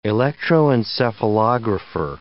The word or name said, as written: electroencephalographer